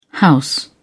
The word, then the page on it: house: British English